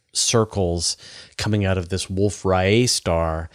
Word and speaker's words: Wolf-Rayet: i'm glad that you said that correctly i i did an interview recently where i just say, kept saying ray it and then after the interview i was like that's not how you say that guy's name it's riot